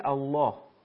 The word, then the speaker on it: الله: زمانی که کلمه الله اگر قبلش فتحه یا ضمه باشه، در این صورت الله لامش درست تلفظ میشه، مثلا هو الله، درست شد؟ – بله.